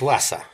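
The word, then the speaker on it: Vasa: У них есть музей корабля «Ваза», я правильно помню, да? – Правильно говорить «Васа», поскольку это название династии, которая одно время правила Швеции, сейчас уже не существует, и корабль назывался в честь нее.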